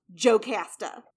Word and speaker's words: Jocasta: She, Jocasta, her name is spelled J-O-C-A-S-T-A. It is not pronounced Jocasta. – Jocasta. – Jocasta. I've also heard Iacasta or Wecasta. Jocasta is the most common one you will hear.